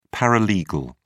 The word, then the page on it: paralegal: British English